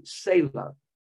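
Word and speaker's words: Tsela: It's T-S-E-L-A. Kind of like the car Tesla, only the S and E are switched around. So it's pronounced as a silent T, Sela.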